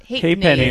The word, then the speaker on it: halfpenny: So you get it's pronounced halfpenny. Halfpenny. Halfpenny.